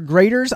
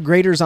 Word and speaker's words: Graeter's: So you can do a search for Grater's Ice Cream. But it's spelled differently than we would expect. – Yes, G-R-A-E-T-E-R-S. Okay, G-R-A-E-T-E-R-S.